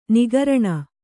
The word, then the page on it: ನಿಃಕರುಣಿ: kannada